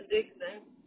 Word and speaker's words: Dixon: Kenya Dixon. – Sorry, can you spell it? – K-E-N-Y-A. Dixon, D-I-X-O-N. – Kenny Dixon. – K-E-N-Y-A.